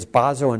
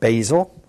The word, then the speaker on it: Basel: And by the way, it's Basel and not Basil. Okay, so it's Bart, not Barth. It's Basel, not Basil, just in case you want the correct pronunciation for these places.